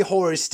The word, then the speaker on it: hoarse: Today I am horse, que se escribe H-O-A-R-S-E. Y se pronuncia igual que caballo.